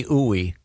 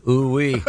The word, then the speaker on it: Ooi: I swear to God, it says, I'm not kidding, Dr. Stephanie Ooey. It's spelled O-O-I. – C-Cruise, right? – Yeah. Ooh-wee. Ooh-wee, baby. Yeah. Ooh-wee. Dr. Stephanie Ooey, there's a pronouncer here, and it says it rhymes with gooey.